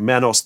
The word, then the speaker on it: Manos: It's pronounced Manos, by the way. – 3 o'clock, The Mads Are Back, Manos, The Hands of Fate.